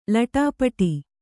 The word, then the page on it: ಲೊಟಪಟ: loṭa paṭa